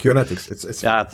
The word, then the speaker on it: Acunetix: Has held R&D, product management, and leadership roles at security software companies, including Acunetix. I don't know if I've said that right. Apologies if I have. – Acunetix. – Thank you. Acunetix, where he managed the development of a leading web vulnerability scanner.